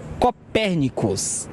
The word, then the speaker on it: Copernicus: Copernicus. Essa é a pronúncia correta.